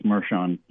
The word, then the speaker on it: Merchan: And by the way, I believe his name is pronounced Merchan, but I've heard it pronounced both ways, but I'm going to go with Merchan, which is how it's spelled in a sense.